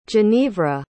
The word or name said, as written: genevra